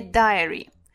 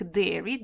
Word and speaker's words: diary: A diary. Diary, обратите внимание на произношение, оно важно, никаких dairy, dairy это молочный, а diary это дневник.